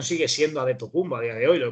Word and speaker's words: Antetokounmpo: Pero realmente la pronunciación correcta sigue siendo adetocumbo.